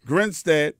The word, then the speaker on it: Grinstead: Am I saying this right? Grinstead? – That's right. – Grinstead.